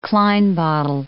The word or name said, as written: Klein bottle